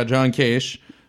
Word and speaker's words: Kasich: Yeah, John Kasich, Bernie Sanders, and Hillary Clinton.